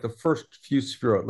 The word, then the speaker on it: sefirot: So do you want to start to go through the sefirot? – Yeah. – Am I saying that correctly? The sefirot? – Sefirot. Got it. You're doing good, brother. – Sefirot. – Sefirot. Okay.